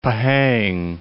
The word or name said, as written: Pahang